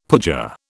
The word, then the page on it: pooja: British English